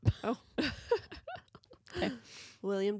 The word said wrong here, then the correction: Willem: Dafoe. Willem? – Willem. – Willem. I said it right in my head and then my mouth changed it.